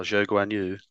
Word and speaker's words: Zhou Guanyu: Do you want to talk about Zhuhan's track? – Oh, Zhou Guanyu.